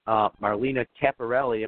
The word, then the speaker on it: Marlena Capparelli: If you, whatever website address, I know I have a link from our, you know, show page, Marlena Capparelli.